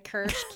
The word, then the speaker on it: kirsch: Am I saying that right, kirsch? – Yeah, I think that is right. Mm-hmm. That's how I would say it. Okay. Marasca, mascara, kirsch, kirsch.